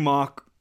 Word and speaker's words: Mauch: Cody Mauch, I think is how we're saying it now, how it's supposed to be pronounced, out of North Dakota State.